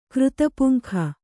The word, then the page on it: ಕೃತಪುಂಖ: Kannada